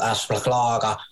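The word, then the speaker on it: Azpilicueta: Aspilicueta. Your pronunciation of him has really improved since we last talked about him. That's great to see.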